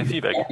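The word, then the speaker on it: Vieweg: And here, although I appreciate him, how do you pronounce him? Vivek, the great biographer today of Hegel, no? – Exactly, Vivek.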